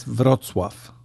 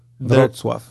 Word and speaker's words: Wrocław: It's Wrocław. Wrocław, yes.